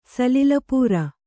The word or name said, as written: ಸಲಿಲಪೂರ